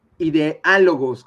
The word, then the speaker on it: Ideálogos: Siempre búsquenos como ideálogos con acento en la A.